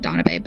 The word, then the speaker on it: Danube: I think that's how you say it, Danube, but it's spelled D-A-N-U-B-E.